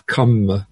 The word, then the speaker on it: kamma: In Sanskrit you get karma, that RM combination is rather a bit difficult to pronounce. So in Pali it becomes kamma, dharma becomes dhamma.